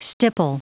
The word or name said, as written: stipple